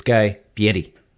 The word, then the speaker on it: Fieri: It's Guy Fieri.